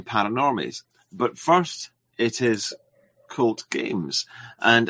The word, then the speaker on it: Cult Games: A lot of people say KVLT games, and I mean, that's fine too, but it's intended to be pronounced cult. – Okay. Well, I should introduce you. – Like in black metal, you know, true cult.